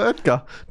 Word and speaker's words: Oetker: Zu Schokopizza von Dr. Digga, ich kann das nicht aussprechen, Mann. – Dr. Oetker. Dr. O.